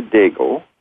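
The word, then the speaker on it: Dego: Francesca Digo, that's D-E-G-O, she pronounces it Francesca Digo, is a 25-year-old violinist who is one of the top violinists in the world.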